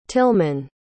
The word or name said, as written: tilman